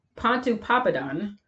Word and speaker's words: Pontoppidan: Pontoppidan, that's how you say his name.